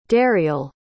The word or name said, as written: dariell